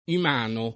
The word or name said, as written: imano